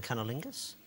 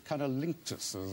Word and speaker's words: cunnilingus: He was completely confused by the term cunnilingus? – He was totally confused. He pronounced it cunnilinctus, as though it were an Irish airline.